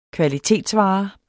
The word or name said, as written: kvalitetsvare